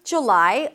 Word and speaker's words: July: The American pronunciation is either a J sound, then the U, as in cook, look, and book. J, J, and then lie, an L sound, and then the I as in my, J, lie. But a lot of people drop the first vowel to a schwa, J, J, July, July.